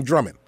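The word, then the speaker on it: Drummxnd: Yeah, it's a Drummond with an X, though, without the O. – Without the O. I'm reading Drummond, Drum X and D. It's just, so that's just saying drumming.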